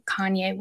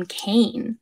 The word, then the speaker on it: Kanye: So I do not listen to Kanye West. I have never heard a Kanye song. I used to pronounce his name Kane because I'm a little dyslexic and that's how I read it.